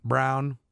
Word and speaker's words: Braun: Like, what are you going to do, run Christian Braun for 32 minutes? I don't think so. Right. – Probably not. – Brown. I got feedback on that. Brown.